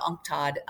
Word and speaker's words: UNCTAD: But I would say one of the most critical right now, not only critical for us, but as you talked about UNCTAD, is how we pronounce it, which is the Commission for Trade and Development for the United Nations.